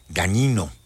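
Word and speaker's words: Dagnino: Perdóname, es el titular del SAT, Antonio Martínez Gagnino, así se pronuncia, es un apellido italiano, GM.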